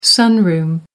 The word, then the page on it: sunroom: American English